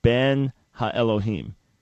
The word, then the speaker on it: Ben HaElohim: But the right way to say Son of God is Ben HaElohim. Ben HaElohim. Right, you had a ha in front of the ben.